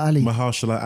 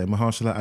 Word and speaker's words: Mahershala: But this one is going to be, it's going to star Marshall, I always get his name wrong, Mahershala Ali. – Yeah. – Yeah, Mahershala Ali.